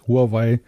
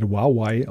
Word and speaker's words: Huawei: Darf ich noch ergänzen, dass das Huawei, wie es ja im Deutschen oft genannt wird, in Wirklichkeit Huawei ausgesprochen ja, wird?